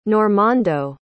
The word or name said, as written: Normando